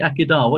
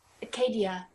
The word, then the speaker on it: Akedah: By the way, how did you pronounce that? I say Akedah. What do you say? – Acadia. – Acadia. – Okay. It doesn't matter.